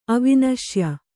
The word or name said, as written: ಅವಿನಶ್ಯ